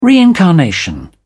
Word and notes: reincarnation: British English

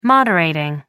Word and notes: moderating: American English

